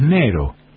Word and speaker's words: negro: Sí, que aparte se pronuncia sin la G, es Nero.